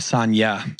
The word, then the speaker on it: Sanja: Uh, the is like a Y, Sanja.